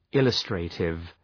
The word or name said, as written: illustrative